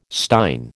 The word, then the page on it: stein: American English